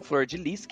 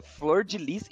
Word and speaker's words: Flordelis: Durante o programa, Flor, Flor de Lis, que pasme, se pronuncia Flor de Lis e não Flor Delis, como a gente comentou, tentou matar o marido sutilmente envenenando ele.